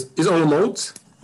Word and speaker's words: Olomouc: Olomouc? – Yeah, it's Olomouc.